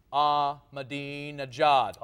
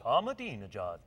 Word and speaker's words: Ahmadinejad: It's like the old Beatles song, Ahmadinejad. – Ahmadinejad.